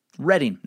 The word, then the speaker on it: Reading: Reading, not reading, like it's spelled.